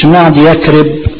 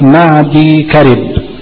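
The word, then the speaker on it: معديكرب: طبعا بعل بك مدينة في الشام، وحضر موت مدينة في اليمن، ومعدي كرب اسم من أسماء العرب، اسم رجل يعني، ما تقولش معدي يكرب، لا معدي كرب، هي بترسم هكذا لكن نطقها كما سمعتم معدي كرب، لا معدي كرب.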